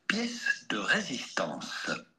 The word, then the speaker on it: pièce de résistance: In French, this would be said pièce de résistance.